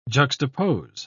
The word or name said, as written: juxtapose